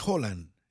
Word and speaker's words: Haaland: Haaland dice que la pronunciación es Haaland. Si usted está en Noruega tiene que decir Haaland, porque usted dice Haaland probablemente lo jalen, no sí, entienden.